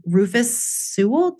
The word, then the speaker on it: Rufus Sewell: Catherine McCormick, Rufus Sewell.